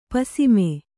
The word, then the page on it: ಪಸಿಮೆ: Kannada